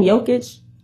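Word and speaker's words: Jokić: Jokic? – Yes. – I think it's going to, see, that's how much I don't care about the man. but I think it's going to be Jokic again, but I want it to be Tatum.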